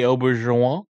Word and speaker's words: Auberjonois: Rene Auberjonois.